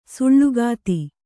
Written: ಸೂಲಗಿತಿ

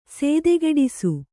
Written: ಸೇದೆಗೆಡಿಸು

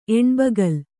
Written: ಎಣ್ಬಗಲ್

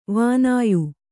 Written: ವಾನೇಯ

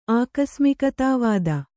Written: ಆಕಸ್ಮಿಕತಾವಾದ

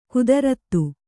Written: ಗತಾರ್ಥ

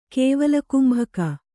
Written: ಕೇವಲಕುಂಭಕ

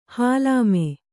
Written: ಹೊಲ್ಲಮೆ

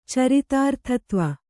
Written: ಚರಿತಾರ್ಥತ್ವ

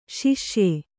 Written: ಶಸ್ಯ

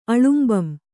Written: ಅಳುಂಬಂ

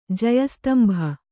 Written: ಜಯಸ್ತಂಭ

